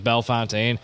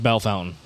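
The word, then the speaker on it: Bellefontaine: There's Bell Fountain, Ohio, and I mispronounced it like Bell Fontaine.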